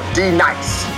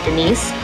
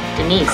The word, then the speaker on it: Denise: Now D nice. – You mean Denise? – Son of a bitch! You say your name right, right now. Denise. Say it right. Denise.